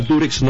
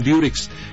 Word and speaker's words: Durex: Así se llama Durex, no Durex. Lo que pasa es que nosotros pronunciamos, al decir Durex, pronunciamos la U como se pronuncia en inglés, U, okay, ¿no? Durex le llamamos.